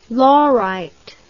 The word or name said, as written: laurite